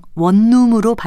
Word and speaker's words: 원룸: 외래어나 외국어에서는 이런 동화 현상의 규정에 얽매일 필요가 없습니다. 그래서 원룸이라고 하지 않고 그냥 원룸으로 발음하는 것이 맞습니다. 또 인터넷 공간에서 많이 사용되는 온라인 같은 것도 그 예로 들 수 있습니다.